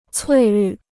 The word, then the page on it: 翠绿: cuì lǜ